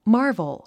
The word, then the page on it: marvel: mɑ’ː r vəl; マーボォ